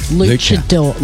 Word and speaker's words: luchador: I've been saying Lucia Fantastica. – Yeah. – And you're saying, no, it's luchador. Like they're luchadors.